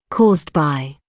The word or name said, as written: caused by